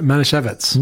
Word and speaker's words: Manischewitz: Manischewitz. – Manischewitz.